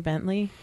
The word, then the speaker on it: Bentley: Well, my last name is actually Bentley.